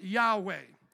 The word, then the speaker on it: Yahweh: That capital L O R D signifies the Hebrew name Yohevahe, the sacred name of God, probably pronounced Yahweh.